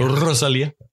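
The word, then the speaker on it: Rosalía: Rosalia. Rosalia. Sådan skal det udtales.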